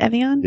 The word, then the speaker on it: Evian: Evian? – Yeah.